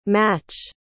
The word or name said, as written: mâche